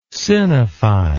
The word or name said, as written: sinify